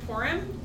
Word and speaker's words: Purim: It's the story of Purim.